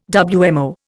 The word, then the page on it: WMO: Indian